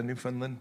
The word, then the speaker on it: Newfoundland: Newfoundland? How do you say that? Newfoundland.